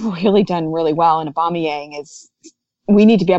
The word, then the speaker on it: Aubameyang: Well, I think it's going to be a combination of both, because and I'm going to butcher his name, Aubameyang. Is that how you say his name? – Yeah.